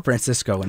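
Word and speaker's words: Francisco Lindor: Who goes away is Francesco Lindor. He's off the table. – Francisco Lindor.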